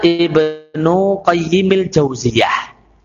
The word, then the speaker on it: Ibnu Qayyimil Jauziyah: Ibnu Al-Qayyim itu ada dua cara baca. Kalau ada jauziyahnya, maka qayyimnya tidak boleh diberi alif lam. Sehingga bacanya Ibnu Qayyimil Jauziyah.